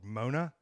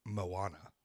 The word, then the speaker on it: Moana: That's too much Moana. Mm-hmm. And I'm glad they spelled it out for us because I thought the movie was called Mona for a long time. It's not how you say it. Moana.